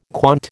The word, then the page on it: quant: American English